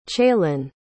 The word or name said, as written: chailyn